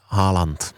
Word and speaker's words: Haaland: Haaland. – A nebo pokud to chcete říkat s tou norskou výslovností Holland, ale je to na vás, Erling v tom taky nemá úplně jasno, si myslím.